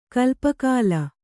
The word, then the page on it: ಕಲ್ಪಕಾಲ: kalpakāla